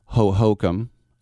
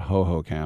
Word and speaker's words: Hohokam: It was a Sunday before I was going to fly home, and I was going to go over to New Hohokam, as the Native Americans say, not Hohokam.